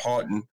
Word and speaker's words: Houghton: Like, you cannot take away the years that Prof. Houghton, that's how you pronounce his name, Houghton, was at Virginia State.